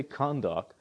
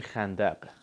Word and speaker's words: Khandaq: Of course, before all of this, he goes to uh, Khandaq, and that's actually how you say it. they actually say Khandak, but uh, it's actually Khandaq.